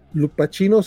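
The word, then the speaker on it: Lupacchino: Por acá nos dice el buen Isaías que Lupaquino se pronuncia como capuchino. Entonces, sí, era como she. O sea, no che, sino she.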